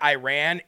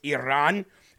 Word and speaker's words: Iran: It's not Iran, it's Iran.